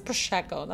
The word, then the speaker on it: Prosecco: It is Prosecco. That's how you say it.